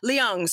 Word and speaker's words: Leung: Luing, L-E-U-N-G, am I pronouncing that right? – Liang. – Okay.